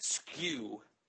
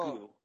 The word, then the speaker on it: SKU: You actually pronounce that skew, not oh. skew. It's pronounced skew.